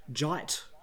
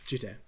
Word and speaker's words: Jitte: So if you do find someone calling it a jite or a jitai or maybe a jeet or a jeet, any of these are very, very much... – Yeah. – Yeah. There's no silent J, silent E or soft J or any of these bizarre pronunciations that people come up with. It is just Jitte.